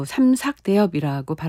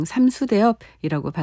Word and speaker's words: 삼수대엽: 삼수대엽 또 삼삭대엽이라고 발음하기도 하죠. 가곡을 하시는 분들은 가곡의 그 발음을 중요하게 생각하기 때문에 삼삭대엽 이렇게 발음 안 하고 그냥 삼수대엽이라고 발음하셔서 저도 삼수대엽이라 발음합니다.